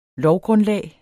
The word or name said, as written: lovgrundlag